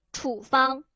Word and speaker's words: 处方: 当它读名词的时候，处哎哎，名词的时候，什么呀？应该读成什么？处方对吧？